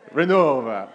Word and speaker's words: Iglesia Renova: they changed their name recently to Iglesia Renova. I don't know if I'm saying that with the Spanish accent. Did I say that right? Iglesia Renova? Yeah. Renova.